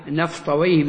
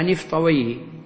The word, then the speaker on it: نفطويه: هذه الأسماء ينطقها نحاة العربية وأهل العربية بفتح الواو وسكون الياء وكسر الهاء، ويه، وهي مع الجزء الأول الذي يشكل معها الكلمة نفطويه بفتح النون أو بكسرها نفطويه، فهي مركبة، فالاسم مركب من نفط ومن ويه.